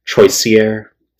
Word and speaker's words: Troisier: Okay, actually, I'm being a fucking asshole. Troisier, okay? Those of you who speak French, no fucking idea, all right? So Troisier is how you spell it.